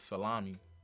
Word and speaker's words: Salami: My last name is Salami. – Okay. – Just like the food, spelled the same way.